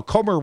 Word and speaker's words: Comer: Comer rhymes with Gomer